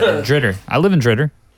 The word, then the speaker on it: DeRidder: That's how you say DeRidder. That's the correct pronunciation.